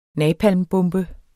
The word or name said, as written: napalmbombe